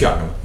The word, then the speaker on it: Yakima: Did I say that right? – It's fine. – Close enough. – What was it? – No, what did you say, Yakima? – Yeah. – Yeah, it's Yakima.